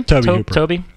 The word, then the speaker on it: Tobe: Just 1 month after Spooky Pinball turned Tobe Hooper's, is that how you say him? – Toby. Yeah, sorry.